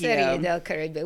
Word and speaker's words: Serie del Caribe: We've got a new business beat with stadium news and also advertising on jerseys and Serie de Caribe? I never say that right. The Caribbean Series. Woo! Cheers. – I think you did that well. – Thank you. – Serie del Caribe.